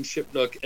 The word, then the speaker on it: Shipnuck: No, it's uh well between Ron Mintz and Alan Shipnuck and all our am I pronouncing that right? – Yeah.